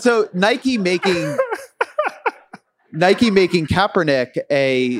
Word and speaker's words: Kaepernick: Did I say it wrong? And it's funny because I've already had it corrected, and I'm not a sports fan. Kaepernick. Sorry.